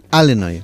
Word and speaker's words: Illinois: Así que, bravo, sin pronunciar la S final, Illinois, una bravo, vez más, bravo, tu apunte bravo, de geografía, bravo, que bueno.